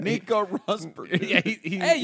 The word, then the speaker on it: Nico Rosberg: And the guy that Nico Rosberg.